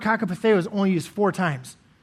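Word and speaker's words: kakopatheo (κακοπαθέω): Well, that word for suffering, it's a Greek word and it's pronounced kakopatheo, and it means to endure evil, to endure hardships, to endure trouble times, to endure evil.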